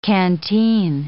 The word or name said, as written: canteen